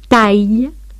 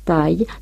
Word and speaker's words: taille: Decimos taille, taille, travail, ailleurs, de manera que AI se pronuncia E, claro está, pero E abierta, pero delante de L o E, suena separadamente las dos vocales.